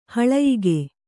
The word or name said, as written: ಹಲಯೋಗ